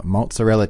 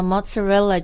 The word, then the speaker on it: mozzarella: It's just tomato sauce, some mozzarella cheese, and basil. – 对，你刚刚讲到 mozzarella cheese, 马苏里拉芝士，这个 pronunciation 和 pizza 也差不多 right? Mozzarella. – Mozzarella, yeah.